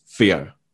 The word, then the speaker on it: Feo: I'd almost always say my name is Theo. We were spelt with an F-E-O, not Theo, with a T-H-E-O, F-E-O.